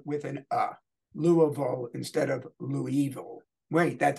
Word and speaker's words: Louisville: Is here with another Studio Simon Stumper live from the studios of Studio Simon in Louisville, Kentucky.